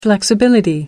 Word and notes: flexibility: British English

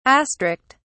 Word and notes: astrict: British English

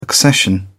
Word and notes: accession: British English

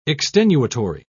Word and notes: extenuatory: British English

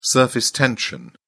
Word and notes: surface tension: British English

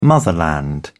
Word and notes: motherland: British English

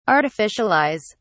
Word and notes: artificialize: British English

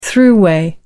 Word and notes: thruway: British English

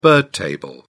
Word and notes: bird table: British English

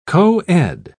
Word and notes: co-ed: British English

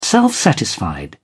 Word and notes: self-satisfied: British English

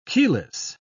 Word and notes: keyless: British English

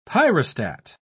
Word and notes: pyrostat: British English

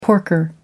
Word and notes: porker: American English

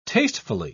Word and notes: tastefully: British English

